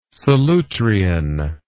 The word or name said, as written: Solutrean